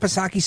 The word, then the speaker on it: Psaki: But Miss Jaffe, Alexandria Jaffe, she um, she asked some pointed questions to Jen Circle back Psaki Silent P,